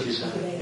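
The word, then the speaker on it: Ghislaine: It's interesting, isn't it, Giselle, is that how you pronounce it? – Giselle.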